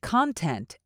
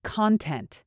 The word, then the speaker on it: content: This word could be a noun, in which case it's pronounced as content. It can also be an adjective, but then you say it like this, content. So in this sentence, because it's being used as a noun, you want to emphasize the first syllable.